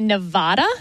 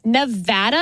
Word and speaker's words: Nevada: was the correct way to pronounce the state that Las Vegas is in, because a lot of politicians would say Nevada. – Wrong. – And that is wrong. – It is Nevada. And I had to train myself to say Nevapple so I could pronounce the A right.